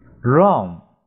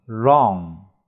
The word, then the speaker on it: wrong: Wrong 不正确，W R 组合这里 W 不发音，日 R NG 要发大嘴巴后鼻音，日 R Wrong。